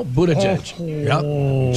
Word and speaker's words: Buttigieg: Did I say his name right, or is it Buttigieg? – No, Buttigieg. – Judge-a-butt? Yep. Backwards? – Judge-a-butt, yeah.